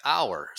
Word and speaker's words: hour: Here, the H is silent. So phonetically, it's like the H doesn't exist. It sounds like hour. So you say an hour.